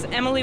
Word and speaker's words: Emilie: Yeah, my name is Emily Wingate, E-M-I-L-I-E.